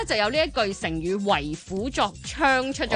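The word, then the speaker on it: 為虎作倀: 其中一個智囊團剛才是不是讀了為虎作伥的、其實它的正確讀音是為虎作槍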